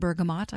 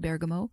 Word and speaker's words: bergamot: Some people pronounce it bergamot. I've always said bergamot.